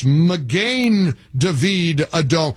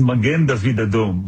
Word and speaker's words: Magen David Adom: I always say Magin David Adom. And somebody in Alpharetta, California, or Georgia said, you're not pronouncing it correctly. It's Magen David Adom.